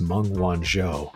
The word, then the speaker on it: Meng Wanzhou: And by the way, I mispronounced her name. Her name is Meng Wanzhou.